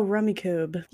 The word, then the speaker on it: Rummikub: Yeah, R-U-M-M-I-K-U-B. – Wait, what? – That's how they spell it. R-U-M-M-I-K-U-B. Rummy Cube? – Oh, Rummy Cube.